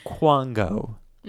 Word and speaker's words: Kwango: K-W-A-N-G-O, Quango, Quango? – Sure. Quango? – Okay. Let's say Quango.